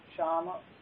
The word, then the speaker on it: Shyama: This Shyam, S-H-Y, long A-M-A, Shama. Shama, Shyam. This is Shyam. There's another Shama, short A, Shama, Shyam. That's Radharani. Shama, Shyam means Radha Krishna.